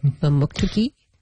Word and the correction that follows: Mamoktokati: Mamoktokati. – Mamoktokati. I missed something in there.